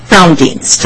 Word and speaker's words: Frowndienst: Frowndienst. Frowndienst. Like if you're frowning and then Dienst.